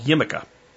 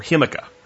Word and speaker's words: jicama: If you want something kind of different and you don't want a lot of carbohydrate, it's called Yimica or Himica, which is spelled J-I-C-A-M-A.